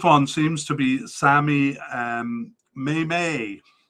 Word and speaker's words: Mmaee: And in the last podcast, I had no idea how to say his last name because it's spelled M-M-A-E-E. You try to say it. Go ahead, try to say it. Yes, you can you? Well, I couldn't either until the African Cup, where I saw that they pronounced it Maymay, Sammy Maymay.